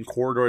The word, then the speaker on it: Quoridor: Corridor, it's spelled weird. It's like Q-U-O-R-I-D-O-R.